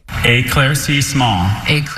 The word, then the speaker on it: éclaircissement: Éclaircissement. – Excuse me, it's éclaircissement.